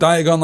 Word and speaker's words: Diagon: Chris says he says the word Diagon like the word polygon.